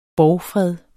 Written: borgfred

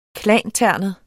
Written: klanternet

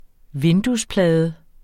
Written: vinduesplade

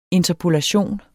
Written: interpolation

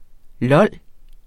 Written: LOL

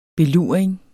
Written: beluring

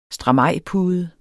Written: stramajpude

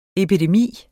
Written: epidemi

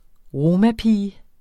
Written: romapige

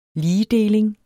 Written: ligedeling

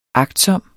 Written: agtsom

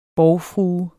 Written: borgfrue

